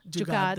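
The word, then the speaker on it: jugaad: Jugad, the double A, yes.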